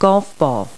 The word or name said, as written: golf ball